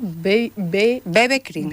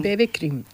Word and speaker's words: BB Cream: Bebe cream. – Bebe cream, eso es.